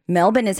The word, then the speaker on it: Melbourne: Melbourne is how you pronounce Melbourne, Australia. – why is there an O-U-R-N-E in it? – I don't know.